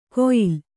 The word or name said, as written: ಕೊಯಿಲ್